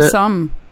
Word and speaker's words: dim sum: Yeah, dim sum.